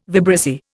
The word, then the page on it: vibrissae: Indian